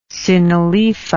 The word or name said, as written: synaloepha